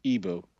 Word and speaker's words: Igbo: Now, when the colonialists came to Nigeria, they couldn't say the word, the sort of a hard GB sound. And so they just said Igbo, I-B-O. So I tend to go with Igbo as a sort of English pronunciation of the word itself.